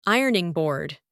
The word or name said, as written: ironing board